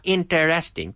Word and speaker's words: interesting: And by the way, three syllables, interesting. Not interesting, no, interesting. Interesting.